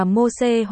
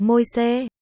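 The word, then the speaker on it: Môsê: Moses, tiếng Latin. Moises, tiếng Việt đọc là Mô-xê hoặc Môi-xê.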